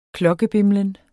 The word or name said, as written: klokkebimlen